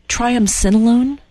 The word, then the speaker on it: triamcinolone: Is it triamcinolone? – Yes, that's it.